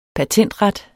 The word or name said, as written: patentret